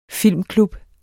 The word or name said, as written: filmklub